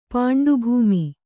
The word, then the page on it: ಪಾಂಡುಭೂಮಿ: Kannada